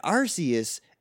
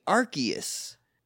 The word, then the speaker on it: Arceus: But it has little pronunciation keys, and Arceus is actually Arceus. – Oh. – Which is wrong, because it's Arceus.